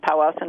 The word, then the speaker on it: Powassan: I'm not sure I'll pronounce it right, but something called Powassan. – Yes, indeed, Powassan virus. And so you're right. It is more severe for people who actually develop symptoms. In Wisconsin, we've had only 25 cases of Powassan virus since people started looking, which I think was about 2003.